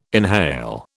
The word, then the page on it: inhale: British English